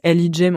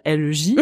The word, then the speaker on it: LEJ: Alors les gens en général ne savent pas comment on se prononce notre groupe, donc c'est LIJ, mais on peut aussi dire L-E-J, voilà. Et nos réseaux sociaux, euh, c'est soit L-E-J, soit euh, Lucie, Elisa, Juliette, notamment sur Instagram.